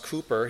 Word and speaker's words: Cowper: William Cooper, you may not recognize the name, but William Cooper, because it's spelled in our hymnals as Cowper, but his name is pronounced Cooper.